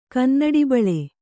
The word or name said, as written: ಕನ್ನಡಿಬಳೆ